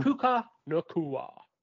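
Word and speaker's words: Puka Nacua: Puka Nakua.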